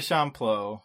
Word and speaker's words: Champloo: Yeah, but let's talk about Samurai Champloo.